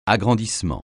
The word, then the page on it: agrandissement: French